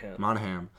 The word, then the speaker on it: Monahan: Monahan.